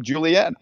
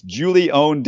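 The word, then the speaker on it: Julién: he pronounces his name this way. And I got the impression from the article that that wasn't widely known. And I talked to the Bucknell voice, and he said, yeah, I've been calling him Julianne. I guess I've been calling him the wrong name. Tell me what they say. And that is exactly what it is. It's Julione Davenport.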